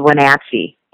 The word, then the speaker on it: Wenatchee: Yes, it is called Wenatchee.